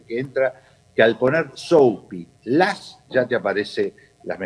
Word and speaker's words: Soapy: en el buscador pones Soapy que se escribe S-O-A P-Y, Soupy, como si fuera diminutivo de sopóperas,